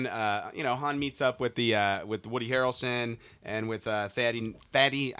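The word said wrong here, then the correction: Thandie: I can never say her name. Tandy. There we go.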